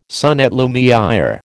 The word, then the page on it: son et lumière: American English